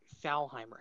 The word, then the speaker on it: Thalheimer: Oh, I'm going to butcher this, but I'm going to try. Thalheimer. How did I do? – Pretty good. Pretty good. That'll work.